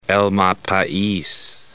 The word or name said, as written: El Malpais National Monument